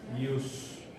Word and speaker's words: ius: Jus va con I, no va con J. ¿Verdad? Va con I. Jus con J es para los americanos. Pero el término correcto, y por la pronunciación del inglés, pero el término correcto es I-U-S. Jus.